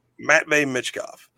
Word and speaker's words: Matvei Michkov: Matvei Michkov.